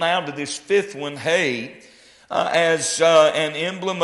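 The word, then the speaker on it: He: It is, we would say H-E-H, but it is the Hebrew letter pronounced hey.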